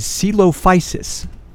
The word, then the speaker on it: Coelophysis: The proper pronunciation is Coelophysis.